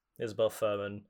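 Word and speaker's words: Isabelle Fuhrman: Isabel Furman? – Yeah. – Is that how you say her name? – Yep. Isabel Furman.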